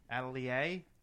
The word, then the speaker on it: Atelier: It's Atelier. – Atelier? – Atelier.